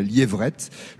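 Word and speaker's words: Liepvrette: Liévrette, il y a un P mais qu'on ne prononce pas, donc c'est la Liévrette, le Val d'Argent.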